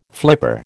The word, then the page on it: flipper: American English